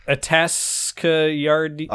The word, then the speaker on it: Atascadero: Are you talking about Atascadero? – Okay, fine, I'll try. Okay. Yeah, I'm talking about Atascadero's Atascadero, there you go.